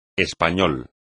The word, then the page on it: español: espagnol